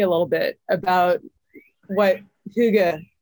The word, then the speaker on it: Hygge: And am I even pronouncing that correctly? – The right way will be Hygge. – Hygge. – Hygge.